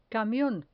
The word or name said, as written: camion